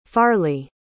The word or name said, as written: farleigh